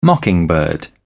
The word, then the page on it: mockingbird: British English